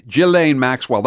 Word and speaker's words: Ghislaine Maxwell: I'm going to butcher the name, but I think it's Ghislaine Maxwell. – Ghislaine Maxwell. That's close enough.